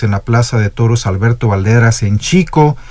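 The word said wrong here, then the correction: Xico: allá en Veracruz, en Jico, ya también nos hacemos bolas con la pronunciación de la X, en Jico,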